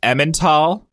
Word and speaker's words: Emmental: It's pronounced Emmental.